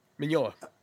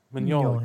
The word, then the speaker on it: Mignola: Mignola. – Mignola.